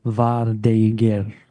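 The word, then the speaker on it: vardøger: I say Vardoger. – Okay. – But the source, the actual word is pronounced something along the lines of Vardeger. So it's kind of a Norse word. But I think it's easier to stick with Vardoger.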